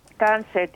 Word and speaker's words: tansi: Tansé means hello in our language. – Tansé, okay. – Tansé, T-A-N-S-I.